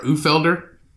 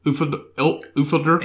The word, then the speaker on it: Uhlfelder: This man, Daniel U-H-L-F-E-L-D-E-R, Ufelder. Ufelder.